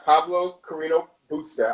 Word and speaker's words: Pablo Carreño Busta: So for the men, you know, we have Pablo Carreño Busta.